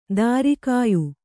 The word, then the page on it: ದೂರಗೆಯ್ಯು: dūrageyyu; kannada